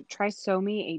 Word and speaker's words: trisomy: Trisomy? Do I say it right? Trisomy? – Yeah, so trisomy 18.